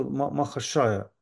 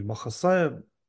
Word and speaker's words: Махашая: Я так вкратце скажу, что Лагири Махашая, на хинди пишется Махашая, индусы так пишут. Вот. Но, видимо, там вот эти транслитерация, да, многие Махасая произносят.